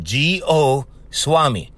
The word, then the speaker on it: Goswami: Now, if you guys like know, so his last name is spelled G-O Swami. Okay? You know what's spelled S-W-A-M-I, Swami, right? Goswami.